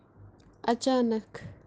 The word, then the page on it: achaanakh: kashmiri